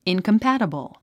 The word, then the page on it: incompatible: ìnkəmpǽtəbl; インコンパァタボォ